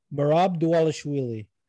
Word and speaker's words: Merab Dvalishvili: merab merab dvalishvili Dvalishvili.